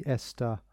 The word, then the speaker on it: ESTA: It is specific countries that are part of the ESTA visa waiver program. That's the way to pronounce it.